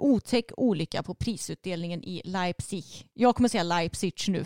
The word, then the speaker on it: Leipzig: Leips? Leipzig. Leipzig. Ja, du, du är snart där. Du klagar så mycket på mitt uttal. – Jag försöker. Jag sa ju att du snart är där. Leipzig.